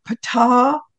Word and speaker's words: Ptah: In fact, Ptah's name is spelled P-T-H, like you're spitting out a seed, Ptu, you know, like the earth is made from a watermelon seed, I like to think.